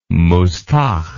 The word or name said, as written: mustagh